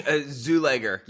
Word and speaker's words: Czuleger: Czuleger.